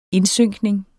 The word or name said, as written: indsynkning